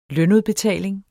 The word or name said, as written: lønudbetaling